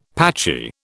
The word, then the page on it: patchy: British English